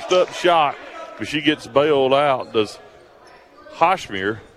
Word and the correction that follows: Hosmer: by the – is that how you pronounce that? How you pronounce that, Coach? – Number 14, Hosmer. Hosmer.